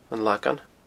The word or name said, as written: anlagan